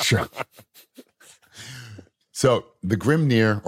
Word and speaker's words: Grimnyr: And if I'm mispronouncing Grimnir, sorry, I'm just G-R-I-M-N-Y-R. – You were spot on, my friend.